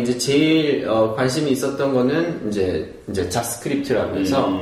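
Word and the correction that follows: Jot Script: 어, 이 발음 조심해. 네, 네, 자트스크립트라는 음.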